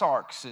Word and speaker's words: sarx: Now, in the Greek or the original language, the word flesh is pronounced sarx. It's S A R X.